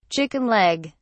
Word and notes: chicken leg: British English

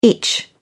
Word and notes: itch: British English

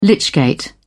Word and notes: lychgate: British English

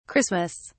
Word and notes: Christmas: British English

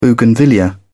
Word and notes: bougainvillea: British English

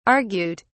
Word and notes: argute: British English

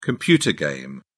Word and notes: computer game: British English

